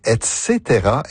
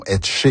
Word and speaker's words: et cetera: Comment doit-on prononcer l'abréviation latine ETC point? On prononce et cetera et non et che.